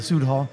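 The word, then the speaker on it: Süd Hall: And it's speaking on your growth, you said you opened the Sud Hall. I didn't know how to pronounce it at first. I saw the umlaut, so I figured it was a long U.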